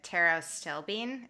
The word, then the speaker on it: pterostilbene: So I don't know if I'm saying this correctly, but pterostilbene, is that right? – Yeah, you got it.